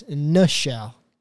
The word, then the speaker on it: Nichelle: It's not Michelle. It's Nichelle. Spelled the same way as Michelle, but it's an N instead of an M.